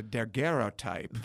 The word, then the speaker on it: daguerreotype: The daguerreotype.